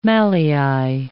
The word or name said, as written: mallei